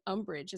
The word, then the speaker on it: Umbridge: I couldn't stand Umbridge.